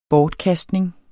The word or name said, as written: bortkastning